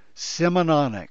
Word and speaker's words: Simononic: It rhymes with supersonic, so say it right now, please. Simononic.